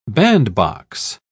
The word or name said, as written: bandbox